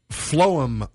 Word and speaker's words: phloem: They're called phloem bundles, or you might decide to be. It's not pronounced like we normally would spell phlegm, but you can get phlegm out of this. P-H-L-O-E-M. – Phlegm. – So phloem, phlegm. Normal phlegm would be P-H-E-G-L-M.